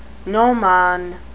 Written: gnomon

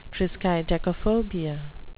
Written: triskaidekaphobia

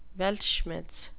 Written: weltschmerz

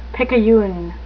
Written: picayune

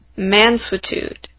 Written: mansuetude

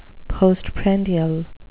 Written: postprandial

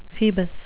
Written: phoebus

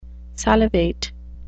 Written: salivate

